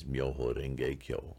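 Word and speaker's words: Myoho Rengekyo (妙法蓮華経): It's a mixture of ancient Sanskrit with ancient Chinese and phonetically pronounced in Japanese as Myoho Rengekyo.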